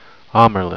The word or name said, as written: amyrlin